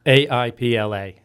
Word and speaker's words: AIPLA: How do you pronounce it? AIPLA? – AIPLA.